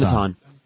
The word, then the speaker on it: Benetton: Am I saying that right, or is it Benetton? – Benetton. – Oh. Well, at least I corrected myself.